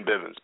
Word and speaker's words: Bivins: It is my full name, Ryan Bivins, B-I-V-I-N-S. But for whatever reason, a lot of people spell it B-I-V-E-N-S.